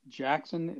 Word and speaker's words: Jaxon: It's Jackson, but it's spelled Jaxon.